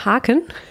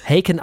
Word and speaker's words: Haken: Ja, interessanterweise haben sie eine britische Band, eine englische Band, die sich nach dem deutschen Wort Haken benannt hat, Haken ausgesprochen wird und deswegen ist natürlich auch Virus.